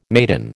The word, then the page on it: maiden: American English